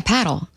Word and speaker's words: padel: Yeah, paddle. It's just paddle. A paddle. It's a racket. – It's similar to pickleball. It's kind of taking over. – Oh, I thought you meant like the pickleball paddle.